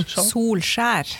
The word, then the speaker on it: Solskjaer: Solskjaer.